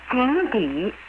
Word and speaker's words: 井底: 井底 is spelled J-I-N-G-D-I, meaning the bottom of the well. 之 is spelled Z-H-I, and is equivalent to the English preposition of.